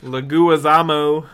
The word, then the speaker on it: Leguizamo: What'd you say? John Lozo? – Leguizamo. – That's not how you say it. – Leguzma.